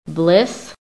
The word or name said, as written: BLISS